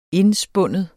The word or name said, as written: indspinde